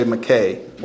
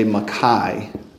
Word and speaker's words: Mackay: Alexander, in English we say McKay, well, in American English. In British English, you say Mackay, but you say that because you're pronouncing his name with Luganda vowels.